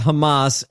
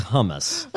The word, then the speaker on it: Hamas: Carson mispronounces Hamas as hummus.